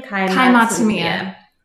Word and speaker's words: Matsumiya: How do you say his last name? – Kai Matsumiya.